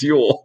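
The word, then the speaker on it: Jöl: So the book is called Yule, issue one, but it's spelled J O L with the double dots over the O, and it's pronounced Yule.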